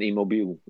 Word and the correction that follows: Immobile: Uh, immobile, ja. – Immobile. Hoe spreek je dat uit? Ja, Immobile.